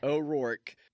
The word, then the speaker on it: O'Rourke: O'Rourke.